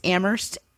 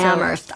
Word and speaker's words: Amherst: It's Amherst, Massachusetts. The H is silent. So. – Amherst.